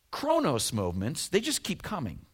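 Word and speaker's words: chronos (χρόνος): The first one is pronounced chronos.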